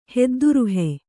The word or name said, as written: ಹೆದ್ದುರುಹೆ